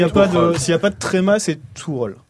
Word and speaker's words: Tuchel: Donc, on se met d'accord sur la prononciation, histoire qu'on soit homogène. – Alors, Jean-Charles Sabatier et David Lortolari, qui pour moi sont les deux meilleurs spécialistes du foot allemand, disent Tuchel. – Bon. Tuchel, ok. Voilà.